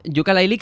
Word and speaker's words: Yooka-Laylee: Efectivamente, todos os burlasteis de cómo yo pronunciaba Yooka-Laylee, que al final es como lo hemos pronunciado todos. Aquí no, hay que decirlo como si fuera ukelele, no sé qué.